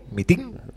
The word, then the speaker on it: mitin: ¿Cómo es? mitin. Mitin. con acento en la última I. Sí, en el, la, del DNG ING. Mitin. – No, mitin en español.